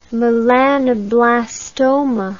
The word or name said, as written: melanoblastoma